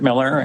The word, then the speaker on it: Miller: My name is Kurt Miller. It's spelled K-U-R-T, Miller, M-I-L-L-E-R.